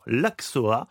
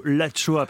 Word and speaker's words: axoa: Vous nous parlez aujourd'hui, d'une recette que, évidemment, Stéphane ne connaît pas, puisqu'il l'a appelée tout à l'heure l'Axoa. Et c'est plutôt l'Achoa, puisque nous sommes dans le Pays Basque.